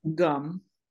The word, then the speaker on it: Ngum: Gum. It's N-G-U-M, and it's pronounced N-G-U-M.